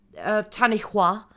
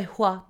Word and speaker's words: Tanihua: I have to say hua because of the putting so much emphasis on the H. – Okay. – I'm just saying it. Tanihua. – Tanihua. – Tanihua. – Tanihua. – Hua. Hua. Like Cool Whip.